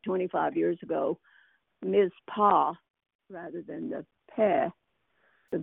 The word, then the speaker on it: Mizpah: I mispronounced it when I was reading it just now. I pronounced it mitzvah.